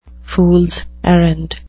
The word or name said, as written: fools errand